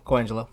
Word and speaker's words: Colangelo: How do you say his last name? Brian Colangelo? – I believe so. – Colangelo, yeah.